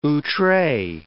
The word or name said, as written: outre